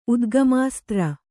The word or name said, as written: ಉದ್ಗಮಾಸ್ತ್ರ